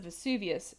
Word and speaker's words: Vesuvius: So, in the case of Vesuvius, there's two main parts.